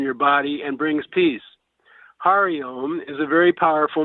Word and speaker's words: Hari: Of course, when you add Hari, Ha, pronounced as in H-O and hot, Re, as in repeat, you get an added effect.